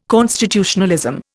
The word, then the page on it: constitutionalism: Indian